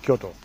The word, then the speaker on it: Kyoto: Kyoto spricht man wahrscheinlich gar nicht so aus, wie ich es ausspreche, oder? Das ist wahrscheinlich sehr deutsch ausgesprochen. – Nö, nö, sehr gut, sehr gut. Kann man so aussprechen. Kyoto.